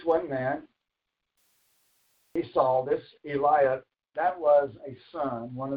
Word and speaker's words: Eliab: And it came to pass when they came in, they saw Ella. Brittany can help me out with this, I'm sure. Brittany, how would you pronounce that? Eliab.